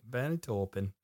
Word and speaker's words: Bernie Taupin: Yeah, Bernie Taupin, I think that's how you spell it. That's how you say his last name. – Bernie Taupin.